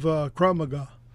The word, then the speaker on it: Krav Maga: it reminds me of Krav Maga.